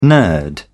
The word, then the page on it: nerd: British English